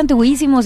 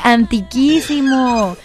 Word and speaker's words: antiquísimo: Antiguísimo, dijo un buen amigo que le dije, no se dice antiguísimo, se dice antiquísimo.